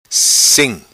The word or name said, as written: Sim